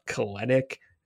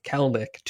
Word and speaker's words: Kelenic: It's not Kelenic. It's not Kelenic. It is Kelenic. Two syllables.